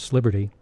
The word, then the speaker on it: East Liberty: What makes the story of The Boxer and the Blonde so wonderfully told is the way DeFord embellishes his narrative with Pittsburghese, East Liberty is pronounced Sliberty,